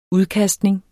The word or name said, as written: udkastning